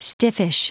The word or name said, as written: stiffish